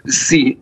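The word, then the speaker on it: si (四): En Chine, par exemple, c'est l'étage 4 qui est proscrit, parce que 4, ça, je vais mal le prononcer, mais ça se prononce si. Et si, ça veut dire aussi mort, à quelques nuances de prononciation près.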